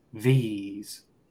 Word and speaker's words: these: También, por ejemplo, un poco de fonética, cuando tenemos la doble E o E space E, E espacio E, se pronuncia I, igual que la letra I, letra I. Entonces, these. Perfect.